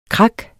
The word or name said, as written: krak